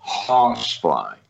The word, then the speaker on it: Hossfly: I tell you what I would tell them to do is to go online and look up Hossfly, H-O-S-S-F-L-Y, Hossfly, H-O-S-S. F-L-Y. Did I say that right? Yes. Hossfly.